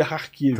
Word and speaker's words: Kharkiv: Ah, É, tá escrito Kharkiv, mas se pronuncia Kharkiv.